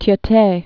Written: Tiete

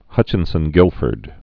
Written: Hutchinson-Gilford syndrome

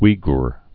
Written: Uighur

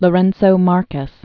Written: Lourenco Marques

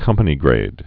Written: company-grade officer

